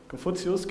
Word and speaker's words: Konfuzius: Da gibt es nämlich diese Konfuzius, oder wie spricht man das auf Deutsch aus? – Ich weiß. – Konfuzius, genau.